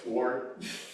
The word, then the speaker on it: sword: That's the proper way you pronounce it, by the way. Sword. Sword.